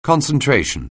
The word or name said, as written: concentration